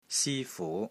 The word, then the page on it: SI1 FU2: Cantonese